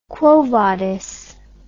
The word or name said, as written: quo vadis